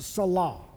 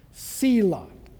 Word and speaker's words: Selah: And sometimes it's pronounced Salah. Selah.